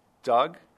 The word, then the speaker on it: Doug: Can you say your name so I pronounce it correctly? – Sure. It's Doug, D-O-U-G, Freed, F-R-E-E-D.